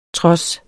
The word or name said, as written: trods